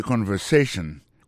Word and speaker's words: conversation: Listen to the conversation. Coutez conversation. Mot conversation, accent tombé sur avant-dernier syllabe là.